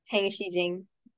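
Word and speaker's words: Tang Xijing: I saw Tan Jing Jing. Is that how you pronounce her name? – Tang Shi Jing.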